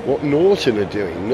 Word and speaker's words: Norton: Have you noticed how I say Norton, not Norton, which is what I used to say? Got to pronounce the T, right?